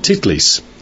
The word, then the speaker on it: Titlis: You're intimately identified with it here in Engelberg, and that's the Mount Titlis. You have to be careful how you pronounce that. That's actually the way it is. T-I-T-L-I-S. We didn't invent it. That's really the name of the mountain.